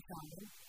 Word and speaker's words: salmon: Yeah, if you hear any noise, it's because we are in the kitchen and we're making salmon. And we're just around the house trying to create a podcast. What do you think? Is that the same thing? – No, you don't pronounce the L.